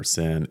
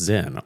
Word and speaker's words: Sinn: I said recently on the show, I never know whether to say Zin or Sin, and someone was like, hey, it's someone's name, asshole. It's pronounced Zin.